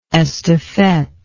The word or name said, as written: estafette